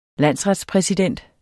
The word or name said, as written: landsretspræsident